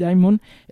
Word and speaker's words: Dimon: Dimon, el presidente de JP Morgan.